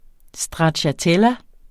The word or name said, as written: stracciatella